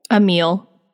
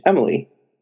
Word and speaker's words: Emilie: It's Emile? – Emile. – Really? Because it's I-E. – I'm looking up a character list because I really genuinely am trying to remember. – Yeah, it's spelled E-M-I-L-I-E. Emily. Emily. Umily. – I think that would still be pronounced Emile.